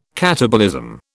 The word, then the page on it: katabolism: British English